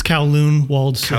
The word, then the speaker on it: Kowloon Walled City: How do you even pronounce it? I don't even want to... – It's Kowloon Walled City. – Kowloon Walled City.